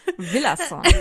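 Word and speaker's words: Villazón: Villason.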